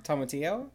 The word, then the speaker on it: tomatillo: These include a spicy queso sandwich, a creamy avocado tomatillo sandwich. Did I say that right? – Tomatillo.